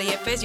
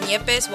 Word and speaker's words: Yepez: So my name is Paula Yepes. You spell it P-A-O-L-A. And then Yepes, Y-E-P-E-Z.